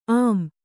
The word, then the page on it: ಅಂ: am